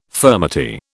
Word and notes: furmety: British English

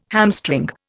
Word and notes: hamstring: Indian

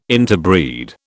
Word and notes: interbreed: British English

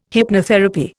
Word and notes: hypnotherapy: Indian

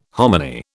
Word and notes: hominy: British English

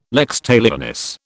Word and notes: lex talionis: British English

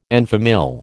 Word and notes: en famille: American English

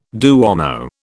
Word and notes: duomo: British English

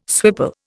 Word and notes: swipple: Indian

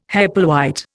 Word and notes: Hepplewhite: Indian

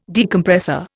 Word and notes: Decompressor: Indian

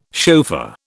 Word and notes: chauffeur: British English

